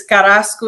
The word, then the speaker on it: Carrasco: Did I say that right? Did I get the Spanish lingo there? – You're very close. You're very close. – Give me a correction so I have it right. – It's Carrasco 67.